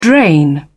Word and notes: drain: British English

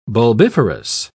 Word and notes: bulbiferous: British English

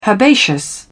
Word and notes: herbaceous: British English

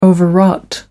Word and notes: overwrought: American English

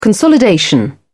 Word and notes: consolidation: British English

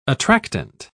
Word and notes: attractant: British English

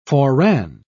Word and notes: foreran: British English

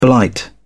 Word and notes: blight: British English

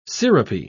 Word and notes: sirupy: British English

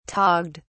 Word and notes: togged: British English

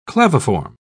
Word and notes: claviform: British English

